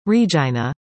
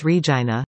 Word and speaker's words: Regina: Experience Regina, the agency responsible for tourism in Saskatchewan's capital, apologized on the weekend and removed the phrases online that seemed to make light of the city's name rhyming with vagina.